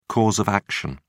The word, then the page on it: cause of action: British English